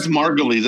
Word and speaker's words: Margulies: Did I say that right? I always think That's Margulies. I think David Margulies.